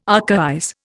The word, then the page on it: archaize: Indian